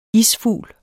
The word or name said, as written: isfugl